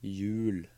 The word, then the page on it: hjul: Norwegian